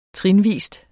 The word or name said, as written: trinvis